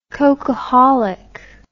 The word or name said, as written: cocaholic